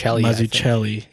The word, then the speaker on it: Mazzucchelli: Mazzucchelli.